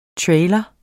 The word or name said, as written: trailer